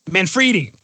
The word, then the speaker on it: Manfredi: Is it Manfredi? – It's Manfredi. – Manfredi, perfect.